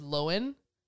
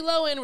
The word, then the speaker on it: Lohan: Lindsay Lohan is Lohan. Like, she's on TikTok now. And she's like, hey, guys, it's Lindsay Lohan.